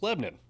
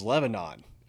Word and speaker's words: Lebanon: If you're new to Middle Tennessee, it's pronounced Lebanon. Yeah, it's funny. You can always tell who is who's from Nashville and who's not by how they pronounce Lebanon. If you're not from here, you look at that and you read it as Lebanon.